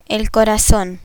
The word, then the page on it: corazon: Spanish